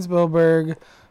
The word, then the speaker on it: Spielberg: I know, okay, so we got Steven Spielberg, Quentin Tarantino...